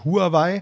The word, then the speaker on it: Huawei: Chinesisch wird es, glaube ich, eher Huawei ausgesprochen. Genau. Wir sagen jetzt einfach ganz klassisch, wie man es spricht. Im Deutschen Huawei, der Smartphone-Hersteller, aber nicht nur das.